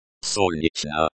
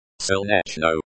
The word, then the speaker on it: Солнечно: Солнечно. Солнечно. The emphasis is on the first syllable. Сол. Сол.